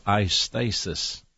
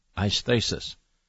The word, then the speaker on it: aisthesis: There's another enemy, too, and that enemy is called eisthesis. That's a Greek word, eisthesis. How do you spell that? A-I-S-T-H-E-S-I-S, eisthesis.